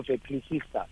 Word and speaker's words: Ecclesiastes: I, I pronounce it as Ecclesiastes. Okay. Ecclesiastes. Okay. Yes. yeah, there she gave you a, round, a, right, a right pronunciation.